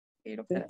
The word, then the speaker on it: quiróptero: que sí, sí lo dije bien, quiróptero.